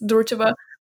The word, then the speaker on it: Durcheva: I'm not really sure how her name is actually pronounced, Anna Durcheva. Yes, Durcheva. Anna Durcheva's story is compared to Kankasa's story is kind of like triggering some thoughts.